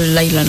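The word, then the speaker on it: Lyla: Pero se escribe Lila, pero sí lo pronuncia como un poquito Laila, ¿no? – Sí, es que va con Y. Laila. – Exacto. – Es Laila y el de Eric Clapton es Leila.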